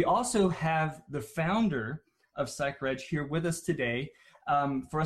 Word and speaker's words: Psychreg: And it's pronounced Psych Reg, not Psych Reg.